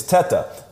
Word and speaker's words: Tedder: The correct pronunciation is Tetta, because right. It's Italian, right? So that's how you would pronounce it. But I think my family pronounces it Tita, because I think Tetta means tit in Spanish.